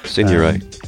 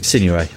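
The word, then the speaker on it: Signoret: Sidney Lumet's espionage drama starring James Mason, Maximilian Schell and Simone Signore. I think that's how it's said. – Signore. – Signore. Okay, thank you.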